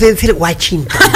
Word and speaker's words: Denzel Washington: No, es Denzel Washington. Denzel Washington, gordo. O sea, aprende a pronunciar.